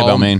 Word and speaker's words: Balmain: But he had on a pair of Balmain, is that how you say it? – Yeah, Balmain.